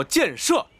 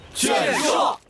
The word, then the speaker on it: 建设: 这两个字念作箭射。 – 箭射。